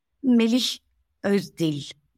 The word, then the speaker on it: Melih Özdil: And also there's a Turkish player who is living in USA now, Melih Özdil. I also love his books. They are mostly in Turkish. – Can you say his name again? – Melih Özdil. M-E-L-I-H is his name. O-Z-D-I-L is the surname.